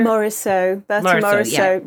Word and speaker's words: Morisot: And Bertha Monso. Is that how you pronounce her? – Morisot. Yeah. So she was actually she was radical for the 19th century.